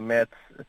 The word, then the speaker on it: Metz: Podíamos ir, não é muito longe, ou seja, a Trier, que é uma cidade alemã que fica 40 minutos, podemos ir a Metz, como se diz com a pronúncia portuguesa, a Metz também é muito bonito e fica tipo uma hora de Luxemburgo.